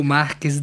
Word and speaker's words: Marques: Hoy estamos en Radio Semilla con Rodrigo Márquez, que yo estoy pronunciando mal. ¿Cuál es tu nombre completo? Bien pronunciado, Rodrigo. – Rodrigo Márquez de Santos de Almeida.